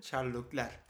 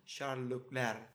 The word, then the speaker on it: Leclerc: Racing Incident genç Sherlock Lerkin de dediği gibi Sherlock Ler K okunmaz bu arada. Herkese bilgilendirme. – Hı hı. – Sherlock Ler diye okunur.